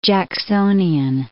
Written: Jacksonian

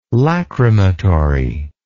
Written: lacrimatory

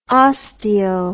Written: osteo

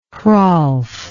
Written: Hrolf

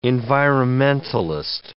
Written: environmentalist

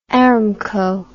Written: Aramco

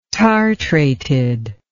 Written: tartrated

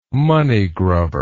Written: moneygrubber

